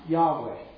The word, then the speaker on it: Yahweh: This name, in Hebrew, we think is pronounced Yahweh.